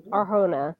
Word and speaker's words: Arjona: Arjona from Hitman, and Katie O'Brien from Love Lies Bleeding.